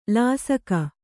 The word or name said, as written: ಲೇಸಿಗ